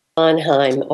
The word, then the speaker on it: Bonheim: Bonheim? – Yeah, it's Bonheim. – Bonheim.